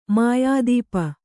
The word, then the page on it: ಮಾಯಾದೀಪ: mayādīpa